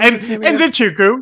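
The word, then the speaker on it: Nzechukwu: Kachuwu, what was the pronunciation? – Enzinchukwu. Yeah, you got it mostly right.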